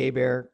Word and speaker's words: Hebert: Well, Bobby Hebert just called and said you mispronounced his name. But other than that, I don't disagree with anything that you just said. – Bobby Herbert. – Bobby Hebert, Cajun. just so everybody knows.